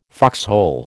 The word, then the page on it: foxhole: American English